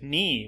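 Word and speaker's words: knee: Yeah, which is cognate with ni, which has a silent k, which used to not be silent and was kni.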